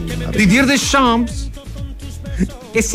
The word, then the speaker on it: Deschamps: Didier Deschamps. – Ah. – Lo pronuncié bien, ¿o no? – 10 de 10. – Deschamps. Como René Descartes, es René Descartes.